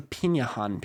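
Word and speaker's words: Pirahã: about his years living among the Pinahan people of the Amazon rainforest. I'm pretty sure I pronounced Pinahan right, but it's spelled like piranha without the N.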